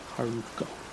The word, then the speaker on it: Haruko: H-A-R-U-K-O. Haruko.